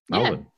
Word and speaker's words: Melbourne: Americans seem to say it the most phonetically right, probably. Melbourne. They pronounce every bit of it.